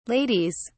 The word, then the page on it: ladies: British English